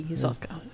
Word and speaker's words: Zuehlke: And one of our Mickey Milers, whose name I forgive me if I mispronounce, his name is Mark Zulka. Z-U-E-H-L-K-E. Zulka.